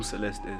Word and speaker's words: Sylest: You're still telling us who Sylest is. – Oh, okay. So my name is actually pronounced as Sylest. Most people mistaken it for Sylvester.